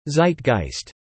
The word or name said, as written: zeitgeist